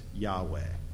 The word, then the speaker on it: Yahweh: In the text, God's name is represented by four Hebrew letters that, to the best of our approximation, is pronounced Yahweh.